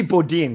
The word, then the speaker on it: Bodin: And it's got to be Bodin, because like Sedin, Rodin, Dahlin. Yep.